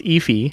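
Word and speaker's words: Efi: It's correctly pronounced Effie.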